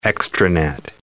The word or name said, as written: extranet